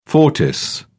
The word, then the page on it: fortis: British English